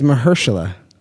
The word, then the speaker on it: Mahershala: It's Mahershala. I'm telling you.